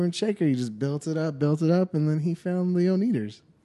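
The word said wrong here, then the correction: Oneders: And the Wonders, it's pronounced.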